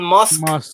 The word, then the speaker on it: Musk: ¿Por qué le decís Musk? Pará. – ¿Cómo se dice? ¿Cómo se dice? – Le venía diciendo Elon Musk desde el lunes y yo no te lo quería decir, pero bueno. Perdóname, te lo tengo que decir al aire. Es Elon Musk. – ¿Cómo se dice? – Con K. – Elon Musk. ¿Así? – Sí, ahí va, perfecto.